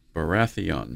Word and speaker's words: Baratheon: So this talks about two bad kings, Jeffrey and Robert Baratheon. Mm-hmm. Is that how you pronounce that? – Yep.